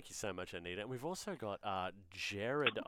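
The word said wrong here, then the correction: Gerhard: Is it Jared? – It's Gearhard, mate. – Oh, I'm so sorry. Sorry, Gearhard, which is a heaps cooler name.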